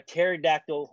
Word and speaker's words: pterodactyl: Why can't you hear a pterodactyl urinate? – I don't know why. Because the P is silent.